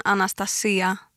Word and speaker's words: Anastasia: Om jag ska på grekiska så säger man Anastasia med betoningen på i-et då.